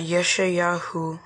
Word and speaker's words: Yeshayahu: It's Yesha. – Yesha. – Yahu. – Yahu. – And anytime you might think you're going to stumble, you can just say Isaiah. – The word that Yeshayahu,